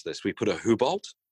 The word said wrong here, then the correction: Hublot: Hubolt. – This is a watch podcast, okay? You need to pretend you're qualified to be on here. Although most people would appreciate that you mispronounced it. – I think Alex saw the photos of before and after of that Hublot with under the plasma cutter. – Hublot.